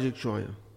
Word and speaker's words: Jequitinhonha: Eu trabalhei no Vale Jequitinhonha. Até hoje. – Não consigo pronunciar isso não. É Vale de quê? – Jequitinhonha. – Jequitinhonha. – Jequitinhonha. – Então, consegui.